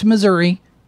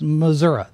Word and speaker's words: Missouri: And she said, People will say it's Missouri. It's not. It's Missouri.